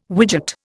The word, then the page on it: widget: Indian